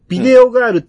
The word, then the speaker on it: 電影少女: 僕、あんまりよく考えてなくて、口につくたびに、ビデオガールって言ったり、うん、電影少女って言ったりするんですよ。 – うん。 – 電影少女と書いてビデオガールと読むと思ってて。